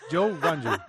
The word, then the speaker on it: Zhou Guanyu: Zhu Guanyu, ¿se dice así? Zhu Guanyu. – Es Zhou. Es como se dice. – Zhu Guanyu Zhou. – Es Zhou Guanyu. Es Zhou Guanyu. Es así.